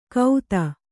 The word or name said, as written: ಕೌದ